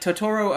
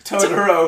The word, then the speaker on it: Totoro: Interesting enough, like, Totoro apparently began and was conceived as a movie without conflict. Why are we laughing? – No reason. – Just a new stress on Totoro.